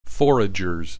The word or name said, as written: foragers